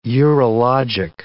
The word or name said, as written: urologic